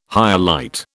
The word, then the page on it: hyalite: British English